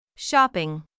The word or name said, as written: shopping